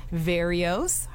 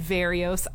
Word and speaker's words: Verreos: It's just Rita, R-I-T-A, and Verreos. I will spell that, although it sounds like Cheerios, it's actually spelled V as in Victor, E-R-R-E-O-S.